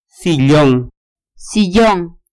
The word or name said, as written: sillon